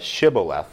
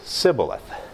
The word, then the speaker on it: Shibboleth: They have them pronounce the word Shibboleth, except that the Ephraimites can't pronounce the SH, so they say Sibboleth.